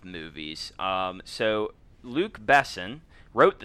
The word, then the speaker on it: Besson: I believe it's pronounced Luc Besson. – Besson, yes.